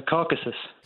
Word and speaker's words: Caucasus: Oh, the Caucasus.